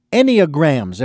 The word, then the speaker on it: Enneagrams: and we're going to talk about Enneagrams, if I've got the pronunciation correct this time.